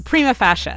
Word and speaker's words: prima facie: I need to be able to pronounce prima facie. – We're not live, are we? Right? Yeah, prima facie. – There we go.